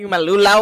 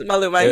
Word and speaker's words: Maluma: Oh, you know, that motherfucker like Malula or something. Sorry. No. Anyways, Maluma, he's a singer.